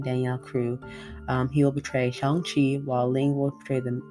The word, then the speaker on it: Shang-Chi: And then we had Shang-Chi, or Shang-Chi is the proper pronunciation of it, that came out in 2021.